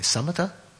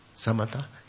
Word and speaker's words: samatha: So if you were to develop, as we talked about in the course, if you were to develop samatha, shamatha, how's it pronounced in Pali? Samatha? Samatha.